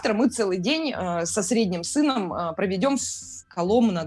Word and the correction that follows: Коломна: да, никак не выучу правильно ударение. – Коломна.